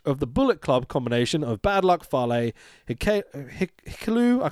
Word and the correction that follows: Hikuleo: Hikuleo, there you go, and Jay White making his return with Gedo versus the Chaos trio of Hiroki Goto, Mikey Nichols.